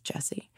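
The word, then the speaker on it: Gyasi: So there's a book, a novel called Homegoing by, now I pronounce her name correctly, Yaa Jessie, I think, G-Y-E-S-I, but I think it's pronounced Jessie.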